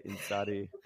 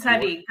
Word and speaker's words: Ntšali: It's spelled Lee, but pronounced Ntšali.